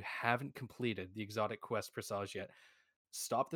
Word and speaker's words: Presage: Like, obviously, I adore talking about it, but what Destiny has brought to the table with the Presage mission is something else entirely. – Sorry, you mispronounced it. – Presage.